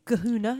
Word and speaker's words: kahuna: But I thought, no, let's do the big kahuna.